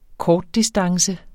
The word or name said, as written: kortdistance